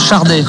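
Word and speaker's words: Sade: Par contre, ça se prononce Chardé, avec un R qui n'existe pas, mais on dit Chardé normalement. Et je crois que d'ailleurs, elle le dit elle-même, Chardé.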